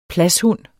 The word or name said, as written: pladshund